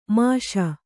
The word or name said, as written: ಮುಸಿ